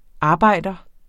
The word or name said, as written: arbejder